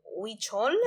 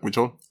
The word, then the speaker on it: huichol: It's called salsa huichol. Yeah. It's like H-U-I-C-H. – Exactly. – It's a huichol? – Yep, huichol.